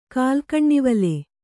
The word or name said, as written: ಕಾಲ್ಗಣ್ಣಿವಲೆ